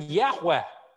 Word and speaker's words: Yahweh: they have no hesitation using Yahweh and building it in to their name. That's how we know it. We pronounce it, the verbal form would have been Yahweh.